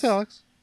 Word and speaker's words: Alyx: I'm just going to say Alyx. – Yeah, it's Alyx.